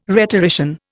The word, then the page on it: rhetorician: Indian